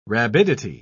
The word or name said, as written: rabidity